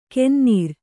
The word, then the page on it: ಕೆನ್ನೀರ್: kennīr